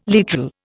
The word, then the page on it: literal: Indian